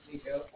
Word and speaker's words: Niko: All right, yeah, like I said, my name is Nico. Oh, I have N-I-K-O.